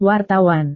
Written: wartawan

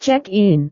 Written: check-in

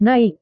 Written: naik

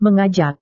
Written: mengajak

